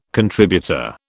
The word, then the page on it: contributor: British English